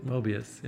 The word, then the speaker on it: Möbius: Is that how you pronounce it? – Yes. – A Möbius strip? – Möbius, yeah.